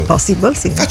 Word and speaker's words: Impossible: 1996, debutta nei cinema statunitensi il film Mission Impossible, ispirato a Normoni. Così si dice, guarda, forse ho detto giusto, vero? – Impossible, sì.